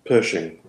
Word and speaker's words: Pershing: And then, you know, not, and then, you know, Massey comes in, and then finally, is it Cushing? Is that how you say it? Go. – Pershing. – Cushing. Okay, like cushions. Okay, Cushing. – Pershing. – With a P.